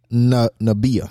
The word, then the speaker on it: Namibia: It's Liberia, Cameroon, South Africa, Namibia. I'm not pronouncing that right. – Namibia.